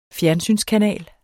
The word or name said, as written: fjernsynskanal